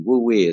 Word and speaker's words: Wu Wei: Wu Wei is how they say it in mm-hmm. China.